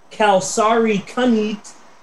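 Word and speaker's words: kalsarikännit: K-A-L-S-A-R-I-K-A-N-N-I-T. It's pronounced kalsari kanit, and it is defined in Finnish.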